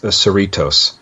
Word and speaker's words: Cerritos: Yeah, no, well, this is, I'm sorry, I said it incorrectly. It's the USS Cerritos. Mm. So that's how you say it.